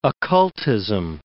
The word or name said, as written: occultism